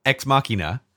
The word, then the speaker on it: Ex Machina: we were debating about how to pronounce, we believe it is pronounced Ex Machina. Or Ex Machinima? But I'm going with Ex Machina, as in Deus mm-hmm. Ex Machina.